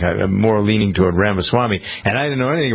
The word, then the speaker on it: Ramaswamy: and Jennifer was telling me, no, it's this way. It's Ramaswamy.